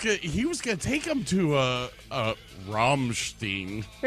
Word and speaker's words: Rammstein: It's pronounced Rammstein.